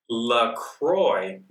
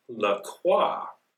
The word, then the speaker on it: La Croix: Oh, see, everyone is pronouncing the brand name La Croix, not La Croix, like the French would say.